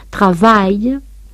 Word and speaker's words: travail: Decimos taille, taille, travail, ailleurs, de manera que AI se pronuncia E, claro está, pero E abierta, pero delante de L o E, suena separadamente las dos vocales.